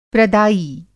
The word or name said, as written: प्रदायी